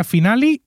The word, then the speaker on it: finale: Finale se pronuncia finale y Ross con genitivo sajón es Rosie's New Girlfriend.